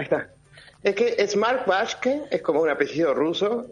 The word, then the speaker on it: Waschke: Que es Mark Waschke. Vos me pronunciarás mejor que yo. Ay, perdón. – Waschke. – Ok, Waschke. Sí.